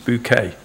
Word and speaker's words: Bucket: So those who haven't, there's a lady called, her real name's probably Mrs. Bucket, but she pronounces it Mrs. Bouquet.